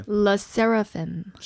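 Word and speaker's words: SSERAFIM: SSERRAFIM.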